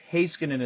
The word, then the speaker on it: Heiskanen: It's Miro Heiskanen is the proper way to say it.